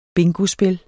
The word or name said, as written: bingospil